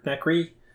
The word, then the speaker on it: nacre: Nacre? Nacre? Nacre? I don't know how to pronounce it. – I don't know how you say it. – N-A-C-R-E. I have never heard of that. So let's go out on a limb and say it's Nasra. that sounds like the least likely way that it's pronounced. – Yeah. Nacre. What I'm finding here on the on the internet. – Okay. Cool. – Nacre or Nacra?